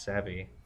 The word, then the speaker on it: savvy: This water's pretty savey. – All right. – Savey? – Savey. – It's pronounced savvy.